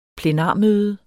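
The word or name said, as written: PLENARMØDET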